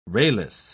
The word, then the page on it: rayless: British English